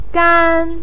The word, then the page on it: gan1: pinyin